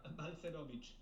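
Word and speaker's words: Balcerowicz: Balcerovic. – Balcerovic.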